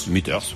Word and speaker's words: Meters: C'est comme ça que ça se prononce. Hein. – Meters. – Meters, ouais, voilà.